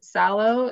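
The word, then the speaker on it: Salò: Salo is how it's pronounced? – S-A-L-O, yeah.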